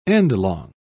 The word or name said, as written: endlong